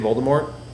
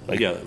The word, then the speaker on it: Voldemort: Isn't that how you're supposed to say it, Voldemort? – You're not supposed to pronounce a T at the end of Voldemort. – Voldemort. – Colbert. Like French.